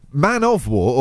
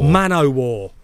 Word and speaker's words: man o' war: Is it man of war or man o' war? – Man o' war. – Oh, Portuguese man o' war. – But I think you say it like man o' war.